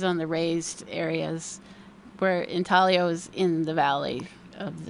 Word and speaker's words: intaglio: What's with the word intaglio, which is spelled, by the way, I-N-T-A-G-L-I-O? – Oh. – Good. But you don't pronounce the G, right? – No, it's Italian.